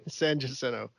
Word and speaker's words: San Jacinto: Are they using the correct Spanish pronunciation? – No, or they don't. They use the Texas pronunciation, San Jacinto.